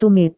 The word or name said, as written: tumit